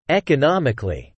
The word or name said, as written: economically